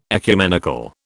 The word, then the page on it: ecumenical: British English